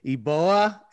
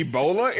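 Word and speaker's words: Eboua: So I'm going to butcher this name too, but I believe it's Paul Eboa, and he's an athletic 6'8 forward. – Did you say Ebola? It is not Ebola. His last name is not Ebola.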